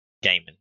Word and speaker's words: Gaiman: I completely finished my Norse mythology book by I always mess up his name, Neil Neil Gaiman. Gaiman, yes.